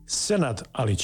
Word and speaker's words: Senad Alic: En met wel Sinat Alic. Zeg ik het goed? Sinat Alic. – Senat Alic. – Senat Alic. Welkom.